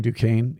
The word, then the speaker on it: Duquesne: You know, the joke was Illinois was playing Duquesne and Illinois won, but they said the loser was going to have to pronounce the S.